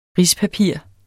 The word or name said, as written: rispapir